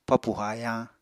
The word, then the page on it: papù hayañ: Apatani